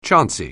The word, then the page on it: chancy: anglais